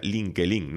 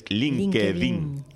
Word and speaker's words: LinkedIn: Y lo he dicho bien porque yo que decía LinkedIn, no, es LinkedIn.